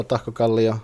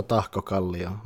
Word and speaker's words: Tahkokallio: That's the way how you pronounce that, all of you, if anyone listens. It's Lautapelit.fi or .fi, and it's Touko Tahkokallio.